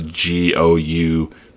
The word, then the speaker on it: goujons: Their chicken fingers are called, I'm going to butcher it, Gojins. I'm sure there's probably some silent letters in there, but it's Goujons.